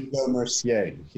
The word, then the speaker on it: Hugo Mercier: Hugo Mercier, The Enigma of Reason. – Hugo Mercier, yeah.